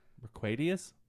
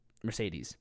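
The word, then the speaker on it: Merck Mercuriadis: No, it's his first name is Merck, M-E-R-C-K. His second name is M-E-R-C-U-I-R-I-A-D-I-S. – Mercquadius? – Mercedes.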